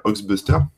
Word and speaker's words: Hoaxbuster: Mais euh, non, non, bah à l'époque, en fait, il y avait un super site qui existe toujours qui s'appelle Oxbuster. Donc ça s'écrit, moi je vais le mettre dans le chat, un c'est H-O-A-X-B-U-S-T-E-R. Ox. donc Ox, c'est un peu les fake news.